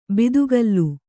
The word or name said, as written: ಬಾತುಗಾಲು